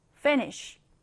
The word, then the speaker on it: finish: 자, 강세는 어디에 들어갈까요? finish. 맨 앞에 넣어주시면 됩니다.